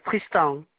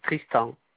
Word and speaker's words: Tristan: Tristan. Comme ça se prononce, Tristan.